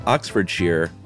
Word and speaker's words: Oxfordshire: The unofficial rule, guys, when standing alone, it's pronounced shire, like wire, but mm. when used as a suffix, it's uh, it rhymes with beer. So Oxfordshire, Worcestershire, obviously instead of Worcestershire, or mm-hmm.